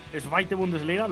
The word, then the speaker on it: Zweite Bundesliga: creo que eran 7 años en la Zweite Bundesliga, lo he dicho bien, señor Tamés. – Perfectamente.